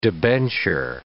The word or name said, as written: debenture